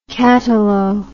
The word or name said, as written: catalo